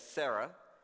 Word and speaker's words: Sarah: We're used to saying it as Sarah, but the way I've been pronouncing it, when it's the R-A-I, is Sarai, and when it's S-A-R-A-H, is Sarah, without the E at the end, but most often we'll just say Sarah.